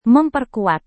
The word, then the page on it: memperkuat: Indonesian